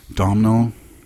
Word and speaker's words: Domhnall: Domhnall.